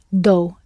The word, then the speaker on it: dou: I give is dou, D-O-U.